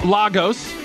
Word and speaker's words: Lagos: It's pronounced Lagos, says the Twitter.